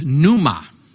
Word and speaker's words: pneuma: It's pneuma, spelled with a P.